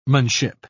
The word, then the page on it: -manship: British English